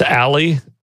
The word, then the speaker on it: Ally: Is it Allio? Is that how you're supposed to say it? Is Allio? It's Ally, Ally, A-L-L-Y dot I-O.